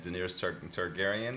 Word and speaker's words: Daenerys Targaryen: Yeah, finally pitting a Lannister and Daenerys Targaryen.